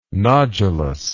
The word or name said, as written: nodulous